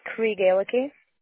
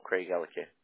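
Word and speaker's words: Craigellachie: It's Craig Ellicky. – Oh, Craig Ellicky.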